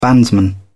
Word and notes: bandsman: British English

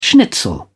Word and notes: schnitzel: British English

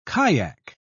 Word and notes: kaiak: British English

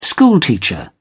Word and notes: schoolteacher: British English

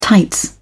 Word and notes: tights: British English

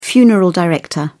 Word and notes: funeral director: British English

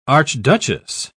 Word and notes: archduchess: British English

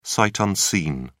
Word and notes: sight unseen: British English

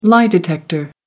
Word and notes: lie-detector: American English

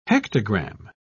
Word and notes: hectogramme: British English

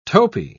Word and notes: topi: British English

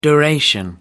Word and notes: duration: American English

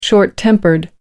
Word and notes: short tempered: American English

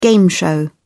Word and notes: game show: British English

